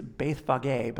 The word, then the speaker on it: Bethphage: We're told that he's headed towards Jerusalem, but he was at Bethphage. You can pronounce it however you want. If you want to get super technical in the Greek, it's Bethphage, but I don't really think you should say that.